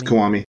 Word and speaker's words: Kiwami: Did I say that right? Kiwami? Kiwami? – Yes, Kiwami? it's Kiwami.